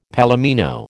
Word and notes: palomino: American English

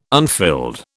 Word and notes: unfilled: British English

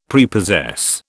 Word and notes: Prepossess: British English

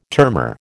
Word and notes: termor: American English

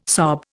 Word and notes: sob: Indian

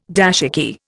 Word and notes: dashiki: Indian

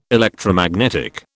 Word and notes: Electromagnetic: British English

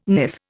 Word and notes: niff: Indian

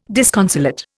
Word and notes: disconsolate: Indian

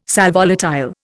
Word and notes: sal volatile: Indian

